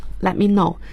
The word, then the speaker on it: let me know: 要注意 let me know，注意他的表达 T 是不发音的， let me know，请让我知道。